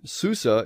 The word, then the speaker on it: Susa: The fact is that both are true, because the ancient Babylonians wrote the word as Shushan, but we have pretty good evidence that they didn't pronounce it as Shu, they pronounced it Su. So while it is actually written in the Hebrew as Shushan, Susa is how they actually pronounced it.